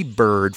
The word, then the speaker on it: Byrd: The album is spelled after his name, B-Y-R-D of Paradise. But the song title is Bird, B-I-R-D of Paradise.